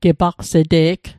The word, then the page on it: gepaqs'te'g: Mi'gmaq